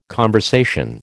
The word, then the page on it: conversation: American English